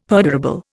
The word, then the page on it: perdurable: Indian